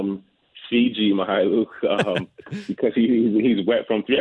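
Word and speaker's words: Mykhailiuk: He's been someone who has really interested me so far this season is Svi, and I always fear that I'm mispronouncing his last name, but Svi Mihailuk, I believe is how you pronounce it.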